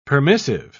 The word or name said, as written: permissive